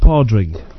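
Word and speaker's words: Pádraig: It's pronounced Podrig. – Podrig. – Yeah. – Podrig and Paddy. – It's actually, when it's called Podrig, it's actually the Irish for Paddy.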